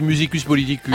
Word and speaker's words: Musicus Politicus: Musicus Politicus, moi je dis Musicus Politicus. – Ah oui, vous dites ça. Oui, oui. – Ah bah la prononciation latine, chère à Najat Vallaud Belkacem.